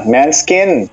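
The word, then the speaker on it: Måneskin: É, main, é Manskin que chama essa banda? Ah, yeah, é, yeah, Manskin.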